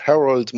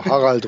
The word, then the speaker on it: Harald: It's much easier with the pronunciation of Harald. Most of the people have a difficulty, especially in connection with my last name, Roehrig. So Harald Roehrig is pretty much too much R's.